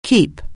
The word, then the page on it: keep: anglais